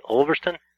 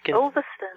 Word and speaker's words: Ulverston: You sent me some quotes that you have, because you're the writer-in-residence at Swarthmore Hall in, I'll try to pronounce it right, Ulverston? – Yeah, that's perfect. Well done. Ulverston.